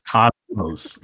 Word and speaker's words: Cosmos: The way that the guy was pronouncing it made me think it was Cosmos, like K-A-S-M-E-S. – No, it's Cosmos. – It's Cosmos, yeah.